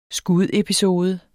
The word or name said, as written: SKUDEPISODE